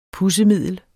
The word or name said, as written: pudsemiddel